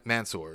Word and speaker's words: Mansoor: Man, Mansoor.